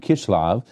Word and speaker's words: Kislev: Now this month is Chislu, or as it's more accurately pronounced in Hebrew, Kislev, but we'll use the English version.